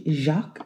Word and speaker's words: Jacques: However, when we take it back to where it kind of began, we, upon research, run into a gentleman named Jack Derrida, if you, Jacques is actually how you say it,